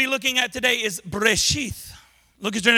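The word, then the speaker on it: Breshith (בראשית): But it's Breshith is how you say it.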